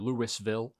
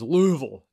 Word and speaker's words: Louisville: I think people get mad when you say Louisville or Louisville. It is Louisville. You got to say it like you're a Southern man.